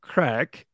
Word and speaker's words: craic: By the way, it's pronounced, it's spelled in a different way. – That's true. – As well. So the Irish word crack, I believe, no, that's Neve.